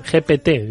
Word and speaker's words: GPT: ¿Por qué querías hablar del chat GTP este? GPT, yo siempre lo digo mal.